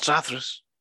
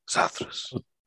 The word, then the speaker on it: Zathras: Not Zathras. Zathras.